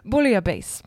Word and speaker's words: bouillabaisse: Bouillabaisse. – Bouillabaisse.